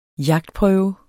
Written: jagtprøve

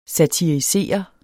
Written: satirisere

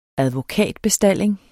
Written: advokatbestalling

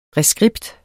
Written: reskript

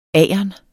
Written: agern

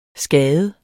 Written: skade

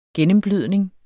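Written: gennemblødning

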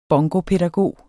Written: bongopædagog

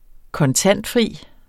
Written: kontantfri

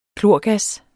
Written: klorgas

